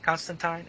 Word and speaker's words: Constantine: So anyway, you got Constantine and – did I say it right? – Tyne. – Constantine? Okay. Good show, by the way.